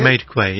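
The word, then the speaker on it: Madekwe: How do you pronounce that, my friend? – Medquay, is that it? – Medquay, yeah. Madkwe. Medquay.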